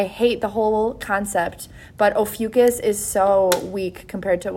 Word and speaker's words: Ophiuchus: So my notes say that I'm going to talk about Ophiuchus. Yes, you guys, if you look that up, the spelling does not give any indicator that it should be pronounced like that.